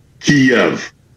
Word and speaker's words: Kyiv: The Ukrainian closest approximation that we can do as non-Slavic speakers is Kyiv. So the Y ends at the first syllable.